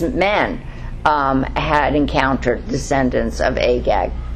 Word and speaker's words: Agag: I'll say it right, Agag. Am I saying it right? Yeah, Agag.